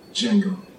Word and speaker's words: Django: Django. Can you spell it? D-J-A-N-G-O. The D is silent.